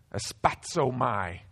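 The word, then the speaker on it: Aspazomai (ἀσπάζομαι): Aspazomai is how it's pronounced in Greek.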